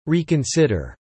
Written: reconsider

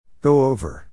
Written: go over

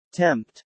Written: tempt

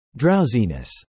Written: drowsiness

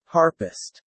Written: Harpist

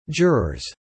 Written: jurors

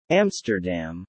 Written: Amsterdam

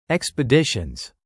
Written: expeditions

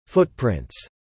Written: footprints

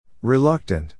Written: reluctant

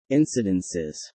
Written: incidences

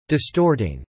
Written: distorting

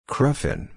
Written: cruffin

